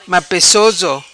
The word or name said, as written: Mapecoso